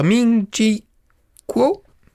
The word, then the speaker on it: Ming-Chi Kuo: Je crois que la première info la semaine dernière a été lancée par Ming-Chi Kuo. Oui. Je le prononce bien. – C'est bien ça.